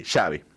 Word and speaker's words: Xavi: Hasta le preguntaron cómo se pronuncia Xavi. porque no tenían bien la pronunciación en el neerlandés, por ejemplo. Así que bueno, dato... ¿Y así es la pronunciación? – Sí, Xavi. – Xavi. – Xavi. Dice, como chocolate, dice.